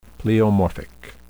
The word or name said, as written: pleomorphic